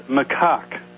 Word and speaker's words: macaque: Are you sure that that's how it's pronounced? – Macaque. Absolutely. As God is my witness.